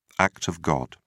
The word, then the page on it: act of god: British English